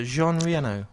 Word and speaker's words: Jean Reno: Jean Reno. Like the car.